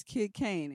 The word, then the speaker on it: Kyd Kane: Uh, my name is Kid Kane.